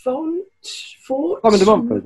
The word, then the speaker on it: Simon de Montfort: Simon de Montfort, yeah.